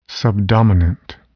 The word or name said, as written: subdominant